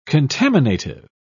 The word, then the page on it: contaminative: British English